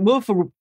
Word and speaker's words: WFRP: Funny enough, we actually pronounce it woof due to a joke in old Games Workshop magazine.